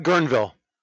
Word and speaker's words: Guerneville: I think that's how you say it, Guerneville. Guerneville? – Yeah, no, you're right, Guerneville. – Guerneville, okay, yeah.